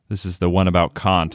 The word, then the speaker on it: Kant: And it's Kant. Like the hard C-A-H-N-T, Kant.